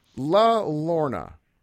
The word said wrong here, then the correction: La Llorona: Did I pronounce that right? Or I totally screwed it up. – Oh, Oh, you're trying to say La Llorona.